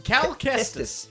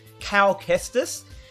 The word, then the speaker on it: Cal Kestis: Cal Kestis. – Cal Kestis.